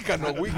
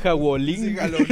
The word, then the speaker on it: Halloween: yo, yo pienso que de verdad José no puede pronunciar Halloween. Halloween. – Ya solo dice Jawolín.